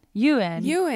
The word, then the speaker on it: Ewan: I really like, I'm going to say his name wrong. I get so paranoid. Ewan. – It's Ewan. – Ewan.